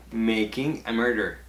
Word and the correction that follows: Murderer: Te falta una sílaba. Sí, es un poco difícil. Si Making a murderer.